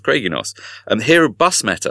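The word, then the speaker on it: Craig-y-Nos: Pronunciation, is that right, Craigie Nos? – Well, I don't speak Welsh, but on the Brecon part, it's Craigie Nos. – Craigie Nos. – It's more like Craig in Germany.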